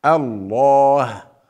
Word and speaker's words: الله: وإذا قيل لهم أنفقوا مما رزقكم الله، انظروا ماذا فعلت، لفظ الجلالة لماذا نفخم فيه الألف، الله، لا نقول الله، إذا فخمنا الألف هنا لماذا؟ لإظهار التعظيم لله عند النطق باسمه، احفظوها هذه، اكتبوها، لإظهار التعظيم لله عندما ننطق اسمه، نقول بدل الله نقول الله، هذا إظهار للتعظيم والتبجيل لله سبحانه تبارك وتعالى.